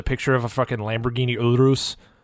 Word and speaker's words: Urus: It's pronounced Urus, but whatever. – Pronounce suck my balls. – I'm gonna pronounce it Urus anyway, so I'm gonna fake a fucking roll on my tongue just to annoy you.